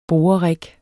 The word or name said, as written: borerig